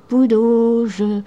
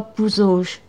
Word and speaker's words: Pouzauges: Alors, il y avait Saint-Aubin, Saint-Martin, La Vérimortagne aussi, Poudauge, Poudauge. Et alors, prononcer Poudauge et non pas Pouzauge.